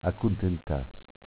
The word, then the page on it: Acuntentass: dialetto